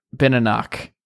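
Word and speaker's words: Benenoch: Benenok to right tackle.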